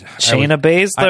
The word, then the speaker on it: Shayna Baszler: Ember Moon will be fighting Cheyenne... – Shayna Baszler.